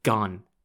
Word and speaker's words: Gun: Gun is spelt G-U-N, and no, it's not pronounced Gun, it's pronounced Gun, and ironically means big fish in Chinese.